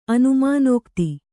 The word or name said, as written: ಅನುಮಾನೋಕ್ತಿ